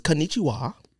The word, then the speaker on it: konnichiwa: Erica says, konnichiwa. Oops, should I say it like that? – Yeah. Okay. That's one foreign word you finally got right.